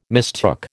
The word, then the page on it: mistook: American English